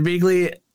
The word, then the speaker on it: Begley: Oh man, Ed Begley's in this movie, and I like Ed Begley or Begley. I don't know why I was saying it wrong.